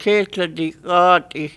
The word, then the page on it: Ketladi Xati: Gull Island